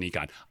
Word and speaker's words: Nikon: Tim Floyd, our dear friend Tim Floyd, says the proper pronunciation is with neither a long nor a short I. It's a contraction of the original company name, Nippon Kogaku, so it's pronounced Nikon.